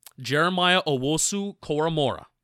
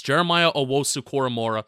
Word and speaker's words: Jeremiah Owusu-Koramoah: JOK, Jeremiah Owusu-Koramoah.